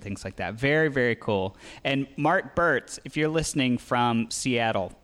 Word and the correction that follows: Burks: Burks, sorry, I think I said it wrong.